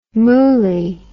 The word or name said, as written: mooli